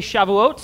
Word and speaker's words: Shavuot: Let me hear you all say Shavuot.